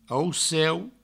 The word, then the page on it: auceu: Provençal